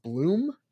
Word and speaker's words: Blum: I think someone told me not too long ago that even though your name is B-L-U-M, it's pronounced Blum.